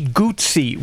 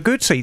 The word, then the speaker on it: Guzzi: We're going to do Moto Guzzi. – Yay! – So is it Guzzi or Guzzi? – It's actually Guzzi. Everyone calls them Guzzis, but it's actually Moto Guzzi. It's simple. Guzzi. Think of pizza.